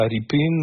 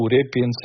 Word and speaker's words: Repin: si vous connaissez cette fameuse tableau de Ilya Repin ou Repin selon les prononciations,